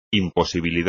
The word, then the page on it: imposibilidad: espagnol